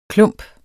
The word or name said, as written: klump